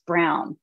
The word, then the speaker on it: Braun: I just recently learned that Braun is actually pronounced Brown, but I'm still reprogramming myself.